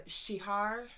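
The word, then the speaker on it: Csihar: So the vocalist Attila Cesar... says, I guess there was a lot of good things in life. I'm sorry. It just says right here how to pronounce his fucking name. Shihar. So, Attila Shihar.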